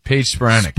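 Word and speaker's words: Paige Spiranac: I always want to say, is it Spironik or Spironik? – Paige Spironik. – Spironik. – Yeah, you always get in my head, make me think I'm saying it wrong. – How do I get in your head? I'm the one who said it right.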